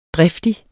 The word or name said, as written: driftig